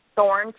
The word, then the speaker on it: Thorne: Well, if you just Google my name, Carly, and I'm going to spell the middle name, A-L-Y-S-S-A, Thorne, T-H-O-R-N-E, you will find me everywhere.